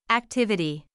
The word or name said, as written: activity